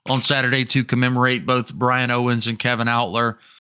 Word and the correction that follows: Outlaw: Outlaw, excuse me. I said it right all night Saturday, and I just messed it up.